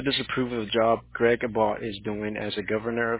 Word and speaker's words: Abbott: What is the proper pronunciation, ma'am? – Abbott. – Abbott. Okay.